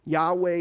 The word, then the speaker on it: Yahweh: Yahweh is the best pronunciation that we have at the moment for his name.